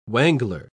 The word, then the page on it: wangler: British English